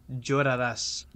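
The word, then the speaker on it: llorarás: Iloraras? Something? Is that it? – Lloraras.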